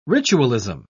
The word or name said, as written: ritualism